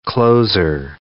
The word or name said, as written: closer